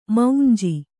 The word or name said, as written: ಮೌಂಜಿ